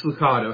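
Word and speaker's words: Lucado: Do we know how you pronounce that? I always hear I've heard multiple ways. – Licato. – Yeah, me too.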